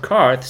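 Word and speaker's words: Qarth: It was painfully slow and boring, and it only led up to a ridiculous town called Carth, spelled with a Q, and its equally ridiculous characters like Zorro Zorro Ducksauce and a magical warlock who didn't know that dragons breathed fire.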